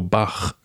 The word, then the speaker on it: Bach: And then B-A-C-H, is it Batch or Bach? – Bach.